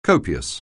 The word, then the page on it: copious: anglais